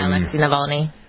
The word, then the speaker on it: Navalny: Alexei Navalny.